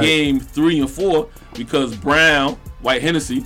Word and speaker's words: Braun: It looked like Braun, but it's pronounced Brown.